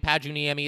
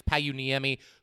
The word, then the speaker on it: Pajuniemi: I was saying Pajuniemi. It sounds like it's Pajuniemi, who actually had a pretty nice game.